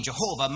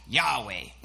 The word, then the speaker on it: Yahweh: So as common as the name Jehovah might be to your ears, it's not actually the name of God. Rather, our best guess for pronouncing God's name is, in fact, Yahweh.